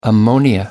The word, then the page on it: ammonia: American English